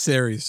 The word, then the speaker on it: Ceres: of Cress. – And so, yeah. – sorry, I think it's pronounced Ceres, just to let you know. Did I miss out a letter?